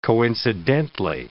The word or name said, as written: coincidently